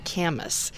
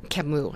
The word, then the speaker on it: Camus: And she's a philosophy major, I think, and she's like, it's not Camus. He was a French philosopher. His name was Camus, I think is correct. And so hopefully I'm getting that close. I needed to get that correct, Steve, because we like to keep things correct. But that was my Western Kansas pronunciation instead of the French pronunciation of his name.